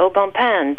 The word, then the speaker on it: Au Bon Pain: Au bon pain. I know people who don't even know how to pronounce it. They call it the pain shop. Pain, because right. The French, P-A-I-N.